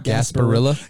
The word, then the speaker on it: Gasparilla: Is that right? Gasparilla Bowl? – I believe that's how you say it. – Gasparilla? – Gasparilla.